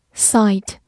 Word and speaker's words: sight: Sight。 s i g h t sight，s 发 s，i 发字母本身的音 i，g h 不发音，末尾的 t 发 t，sight，它是一个名词，表示视力或者景象。